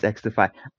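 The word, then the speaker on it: XDefiant: X-Defiant? – Yes, it's X-Defiant.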